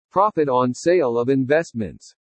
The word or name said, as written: PROFIT ON SALE OF INVESTMENTS